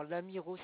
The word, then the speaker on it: Lamirault: 2 rue de l'Amiro. Alors, l'Amiro, ça s'écrit L-A-M-I-R-A-U-L-T.